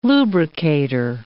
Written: lubricator

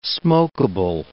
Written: smokable

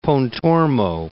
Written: Pontormo

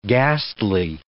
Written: ghastly

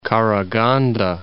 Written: Karaganda

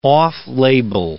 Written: off-label

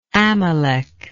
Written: Amalek

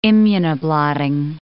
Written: immunoblotting

